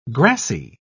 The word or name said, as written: grassy